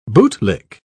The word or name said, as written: bootlick